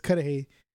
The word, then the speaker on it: Cudahy: But it's Cudahy. It literally is a 5-block radius of a city. – Pretty much, dude. – Sounds like Hideaway. – You could literally put Bell instead of Cudahy and you still get to your house.